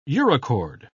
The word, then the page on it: urochord: British English